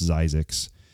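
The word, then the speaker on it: Zzyzx: It's pronounced Zyzix.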